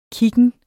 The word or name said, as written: kiggen